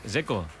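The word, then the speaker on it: Džeko: Dzeko. Bruno dice que es Dzeko. – Como tirando a J, Dzeko o algo así.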